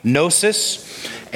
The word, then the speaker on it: gnosis: One word is gnosis, say it with me, gnosis, and epinosis, not gnosis, but gnosis. The G is silent in the Greek.